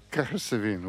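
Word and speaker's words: Cahersiveen: They spent 3 weeks in Cahersiven. – Cahersiven. – Cahersiven. – You said it right.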